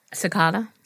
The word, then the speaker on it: cicada: I've never had ticks, but I always say it wrong because of the band. Casada? Casadas? What are they called? – Cicada. – Cicadas. Cicadas? Mm-hmm.